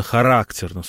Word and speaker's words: характерно: Вот древнерусское искусство, которое очень так характерно, именно характерно, с ударением на второй слог, выразилось в Владимира Суздальском искусстве.